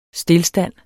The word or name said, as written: stilstand